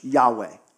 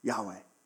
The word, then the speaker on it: Yahweh: But yes, as best we can guess, that is pronounced in English, Yahweh. Yahweh.